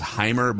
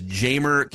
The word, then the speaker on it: Jeimer: What about, and I've heard it pronounced Jamer. I think the correct pronunciation is Heimer, but we'll, so the American way to pronounce it is Jamer Candelario.